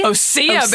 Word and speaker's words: Osea: Osea, baby. – Osea. – I think. I had to watch YouTube to see because I didn't want to say it wrong. – It's spelled just Osea, so Osea.